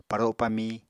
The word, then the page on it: paro pamí: Apatani